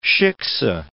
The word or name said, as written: shikse